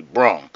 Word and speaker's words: Brung: Without the FTG, just capital B, lowercase R-U-N-G, Brung. That's how you say it, Brung, like I brung you something that you needed.